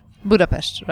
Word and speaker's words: Budapest: Do you say Budapest? – Budapest, right. – Oh, okay. I was pronouncing it wrong.